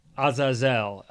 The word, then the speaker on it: Azazel: is the word az Aziel, I think, is how you say it. Azazel, Azazel is okay. how we say it.